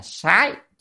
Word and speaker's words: sái: Chữ sát này ở đây có nghĩa là phải đọc âm là sái chứ không đọc sát là sai.